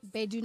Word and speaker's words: Gbedu: So you can follow us at Bedu Nights, G-B-E-D-U and Nights. So the G is silent.